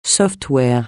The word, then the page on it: software: francais